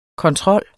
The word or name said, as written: kontrol